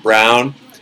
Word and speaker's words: Braun: It's pronounced Brown, Christian, supposedly.